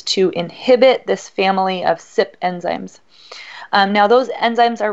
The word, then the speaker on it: CYP: That's abbreviated CYP, pronounced SIP.